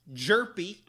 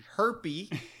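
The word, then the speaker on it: Hjerpe: What you need to know is that it's pronounced Jerpy, not Herpy. – Yes, thankfully. – Jerpy is very loud. It is not silent.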